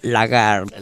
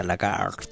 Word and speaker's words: Lagarde: Christine Lagarde. – Lagarde. – Porque la E, viste, se pronuncia Lagarde. Así.